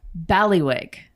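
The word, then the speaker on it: bailiwick: It is Ballywick. – Ballywick? – Yes.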